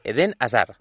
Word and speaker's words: Eden Hazard: Él es belga, se pronuncia, es Eden Hazard. – Hazard. – Esta es la forma correcta de decirlo. – Además, a mí me han comentado algún compañero que tampoco se pronuncia ni la H, por supuesto, ni la D final, casi.